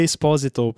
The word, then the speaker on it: Esposito: Eu vi uma entrevista com o Giancarlo Esposito, que inclusive ele pronuncia Esposito, porque é italiano.